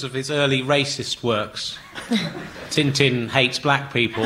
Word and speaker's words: Tintin: I'm going to give you an extra point if you can say Tintin in the Belgian pronunciation. – Tintin.